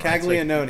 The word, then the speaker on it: Caglianone: I like how you were like, I'm not going to pronounce Jack Caglione, but here's Giancarlo. – Caglione. Oh, wow. See? Caglione. Let this be a lesson. – Caglione. You both got it Caglianone. – Right. So, Caglianone.